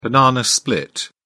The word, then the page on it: banana split: British English